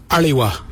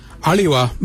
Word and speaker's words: aliwa: It's spelled A-L-I. Now let's add some suffixes with ali. – So if we add W-A, it'd be aliwa. Aliwa means look out in that direction. Aliwa. Now if we were to take out W-A and replace it with D-J-A, then it would be alija.